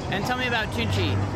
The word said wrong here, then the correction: Tunche: It's a, since the name is in Spanish, it's pronounced Tunche, but we don't mind.